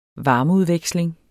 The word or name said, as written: varmeudveksling